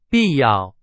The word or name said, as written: 必要